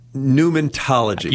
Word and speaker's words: pneumatology: Well, now we're talking about pneumatology.